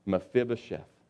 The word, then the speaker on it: Mephibosheth: Now you got to be able to pronounce that, Mephibosheth.